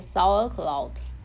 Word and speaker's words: Sauerkraut: 是 sauerkraut。